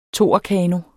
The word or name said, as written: toerkano